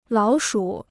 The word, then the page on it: 老鼠: lǎo shǔ